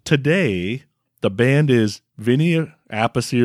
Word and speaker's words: Appice: but Carmine says it's Appice. He just pronounces it Appice because he wanted to separate himself.